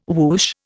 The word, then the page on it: woosh: Indian